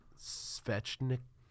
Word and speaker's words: Svechnikov: Oh, Svechnikov.